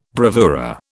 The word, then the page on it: bravura: British English